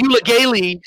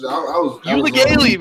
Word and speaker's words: Uiagalelei: Not with bad DJ Uiagalelei starting at quarterback. – You said it right. I can't pronounce the last name. I said you. Origami or some shit. – Uiagalelei, baby.